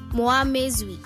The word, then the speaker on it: Muamwezwi: Muamwezi. Muamwezi. – And in Lozi, Muamwezwi. – Muamwezwi. Okay, it's Muamwezwi. Muamwezwi. Zwi, zwi. Muamwezwi. – And in Lozi, Muamwezwi. – Muamwezwi. Okay, Muamwezwi. That's how you say it.